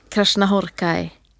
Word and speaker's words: Krasznahorkai: Men i alla fall den här Krash, Krishna Horkai boken, tack. Jag tittade frågande på Maria där, hon nickade bekräftande på mitt uttal.